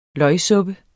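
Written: LØGSUPPEN

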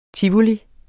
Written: TIVOLI

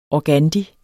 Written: organdi

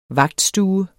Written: vagtstue

